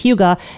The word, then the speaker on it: hygge: I've been to a couple of sites and I've asked for the pronunciation, and it's hygge. It's spelled H-Y-G-G-E.